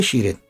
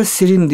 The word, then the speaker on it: Şirin: Mariye ile Şirin. Bazı rivayetler Sirin diye okunuyor, biz Şirin diye tercih ettik.